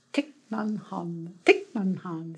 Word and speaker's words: Thich Nhat Hanh: I never say his name right, but I'm going to say it anyway. Thich Nhat Hanh. Is that right? Thich Nhat Hanh. – I think so.